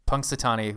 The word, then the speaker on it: Punxsutawney: Punxsutawney, did I say that right? – Punxsutawney, whatever.